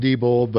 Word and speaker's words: Diebold: And then, but of course, we all know them by the mispronounced name of Diebold. It's actually pronounced Diebold, but I refuse to. Since they killed our democracy, I'm going to call them Diebold.